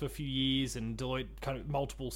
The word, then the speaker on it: Deloitte: I'm not sure about the exact pronunciation, Deloitte Digital? – Yes, Deloitte, yep.